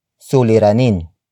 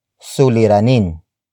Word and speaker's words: suliranin: which is suliranin. Suliranin.